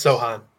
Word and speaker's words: Sochan: Yeah, it's Sochan.